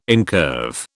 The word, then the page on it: incurve: British English